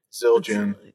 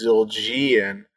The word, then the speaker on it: Zildjian: Now you've got me thinking I'm saying it wrong, and it's Zildjian. – Right. But I think it's Zildjian. – Calls them Zildjian. Yeah. – I think they're just Zildjian.